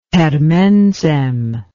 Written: per mensem

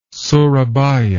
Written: surabaya

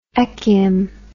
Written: echium

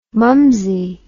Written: mumsie

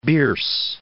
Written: Bierce

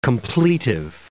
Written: completive

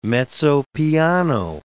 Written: mezzo piano

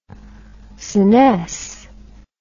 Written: senesce

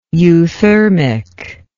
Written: euthermic

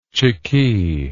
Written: chikee

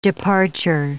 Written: departure

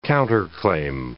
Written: counterclaim

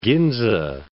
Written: Ginza

